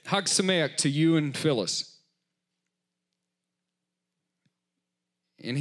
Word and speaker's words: Chag Sameach: I simply said from our Seder at church, and then how do you say this, Audrey? C-H-A-G space S-A-M-E-A-C-H. Haksameach.